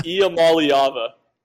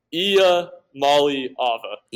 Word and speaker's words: Iamaleava: Also, teach me how to say his last name correctly. – Oh man, I've been in that boat too. Nico Iamaliava. Iamaliava. – Iamaliava. Okay. – Iamaliava. – Iamaliava. Gotcha.